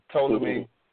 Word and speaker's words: Ptolemy: the P is silent, so it's from Ptolemy, mm-hmm.